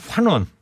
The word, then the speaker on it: 환원: 한원이 아니고 환원. 아, 제가 한원이라 그러지 않았나요? 네. 환원이라고 좀 발음을 좀 해주세요.